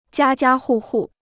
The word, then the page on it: 家家户户: jiā jiā hù hù